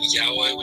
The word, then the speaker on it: yaoi: If it's like a hentai manga, do you call it like yayo or whatever it's called, or what is it? I'm butchering it so hard. I know. – Hentai manga. And I think what you mean yayo, you're referring to yaoi, which is a specific genre of boy on boy stuff.